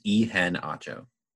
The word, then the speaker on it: Iheanacho: What is it? One more time. – It's Iheanacho. – Iheanacho. Oh, so it's pronounced how it's spelled.